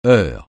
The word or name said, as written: heure